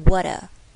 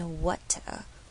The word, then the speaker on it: water: 好，water 也是，如果是美式发音会变成 water water，这边没有，就是 water water glittering assemblage assemblage。